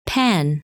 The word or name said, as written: Pan